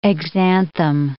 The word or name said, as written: exanthem